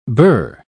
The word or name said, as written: birr